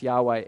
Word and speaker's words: Yahweh: When it says Lord, the actual word there is Yahweh, Y-H-W-H, and we add the vowels A and E in there to pronounce Yahweh.